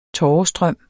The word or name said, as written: tårestrøm